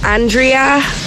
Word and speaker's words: Andrea: My name is Andrea, but... It's spelled A-N-D-R-E-A, and people like to call me Andrea.